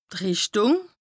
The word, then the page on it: d' Rìchtùng: Bas-Rhin; Strasbourg